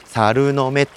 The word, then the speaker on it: SLNM: でこれはですね、えー、すきっらに酒っていうバンドをやっていてかつ「サルノメ」っていう、えー、 SLMN っていうですね逆かえ SLNM かっていうと書いて「猿の目」って読ませる